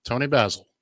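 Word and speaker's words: Toni Basil: Tony Basil.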